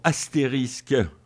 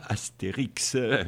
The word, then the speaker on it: astérisque: Et elle dit, ne prononcez pas Astérix, comme on aurait la tendance de le faire.